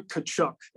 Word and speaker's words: Tkachuk: You want to say that his name for Matthew Tkachuk? It starts with a T. It's very confusing. Matthew Tkachuk. – Yeah, I said it correctly. I said Tkachuk.